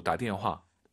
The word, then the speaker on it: 打电话: 발음하실 때 打电, 너무 打电 하지 마시고 힘 빼시고 打电话.